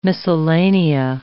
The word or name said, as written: miscellanea